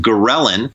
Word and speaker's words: ghrelin: One is called ghrelin, and that's spelled G-H-R-E-L-I-N. And the reason I spell it out is because it starts with a G, so I call it the go hormone.